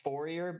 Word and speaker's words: Fourier: So it's spelled Fourier, but it's pronounced Fourier.